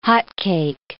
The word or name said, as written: hotcake